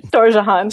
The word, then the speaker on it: Storjohann: Storjohann.